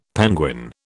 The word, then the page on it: penguin: British English